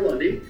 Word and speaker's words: Lunde: Oh, I get Lunde and Lunde and Lundy and everything, so it's all good.